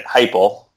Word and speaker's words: Heupel: Heupel.